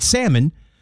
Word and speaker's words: salmon: If salmon, because when you say it right, salmon. So you drop the L, the O becomes an E, and if you're saying it Southern, then the L, you're emphasizing the L.